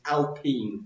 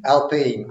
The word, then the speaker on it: Alpine: slightly more recently, Renault are renaming their team I've been told to be pronounced this, Alpine. – Alpine, right, okay.